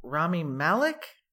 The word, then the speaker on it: Rami Malek: Kyle Chandler, Bloodline, Rami Malek, I think that's how you say it, right? – Yeah.